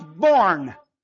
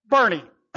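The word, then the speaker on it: Boerne: I found out you guys pronounce Born, Bernie. I'm looking at the map, Born, Texas, no problem. Then all week I've been hearing about Bernie, I'm going, oh, that's what they mean.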